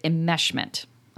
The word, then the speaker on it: enmeshment: I think it's spelled like I-M-M, but it's enmeshment. – Enmeshment. – E-N-M. Mm-hmm.